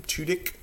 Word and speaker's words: Tudyk: It's got Alan, I can never remember how to pronounce his last name, Tudyk.